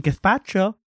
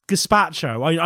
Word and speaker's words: gazpacho: When someone says it too much, it's I'll have some gazpacho. No, it's gazpacho.